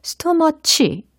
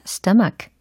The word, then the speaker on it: stomach: 스토머치 이렇게 잘못 발음하시는 분들이 계시더라고요. 스토머치가 아니라 stomach, stomach, stomach 라고 발음을 해주시면 됩니다. S-T-O-M-A-C-H 라는 철자예요.